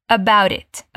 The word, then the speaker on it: about it: Se a gente fosse pronunciar mesmo cada T, ficaria about it. Que também é possível. Mas é bem comum na pronúncia americana não falar esse T no final. Então fica about it.